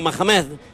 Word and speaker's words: Mohamed: Cuando escuchamos el silbido de Ahmad Mohamed, que es árbitro jordano. Más o menos se dice así, creo, ¿no? ¿O qué? – Sí, efectivamente, muy bien has pronunciado, Mohamed.